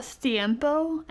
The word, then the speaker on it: Siempo: It's called Siempo, and it sounds like a Spanish word. It's spelled S-I-E-M-P-O, and it's the free app to limit the screen time.